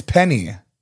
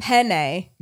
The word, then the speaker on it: penne: You're saying it like P-I-N-A-Y. – Oh, right. Because penne. E-N. Okay, people in Texas, like it's like Ben or a pen.